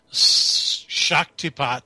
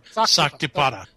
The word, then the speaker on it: Shaktipat: There's a reference to Scatapat. – I think it's pronounced Shaktipat or Saktipata. Saktipata. – So anyway, I looked up what that is.